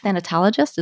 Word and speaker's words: thanatologist: You've been doing this for, for 20 years, and you mention on your site that you're a thanatologist. Is that how it's pronounced? Yes, that's it.